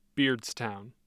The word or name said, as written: beardstown